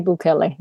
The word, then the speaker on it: Bukele: How did this particular president, is it Bukele? Is that how you say his name? – Nayib Bukele. Yeah.